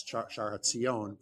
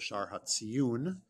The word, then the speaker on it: Shar HaTziyun: Many people mispronounce it as Shar HaTziyon, but it's actually called the Shar HaTziyun.